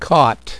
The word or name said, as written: caught